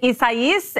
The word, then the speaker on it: Isaias: Right now, Category 3, Isaias is hammering the Gulf Coast with torrential rain and steadily increasing wind. Isaias? – That's how you're supposed to say it, right? – Yes.